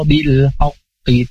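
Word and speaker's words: bil haqqi (بالحق): Kemudian bil haqqi, ini qalqalah kubra atau bisa juga ada yang menyebutnya qalqalah akbar, karena huruf qalqalah kemudian ada tasydidnya dan dibacanya bil haqqi. Bisa bil haqqi kalau tidak ada tasydid ya, tapi kalau ada tasydid jadi bil haqqi.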